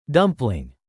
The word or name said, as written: dumpling